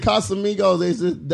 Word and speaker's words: Casamigos: Shout out to Casamigos. That's how you say it.